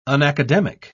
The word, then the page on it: unacademic: British English